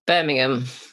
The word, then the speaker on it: Birmingham: Is it Birmingham or Birmingham? – Birmingham. – Birmingham. – Yeah, Birmingham. Okay.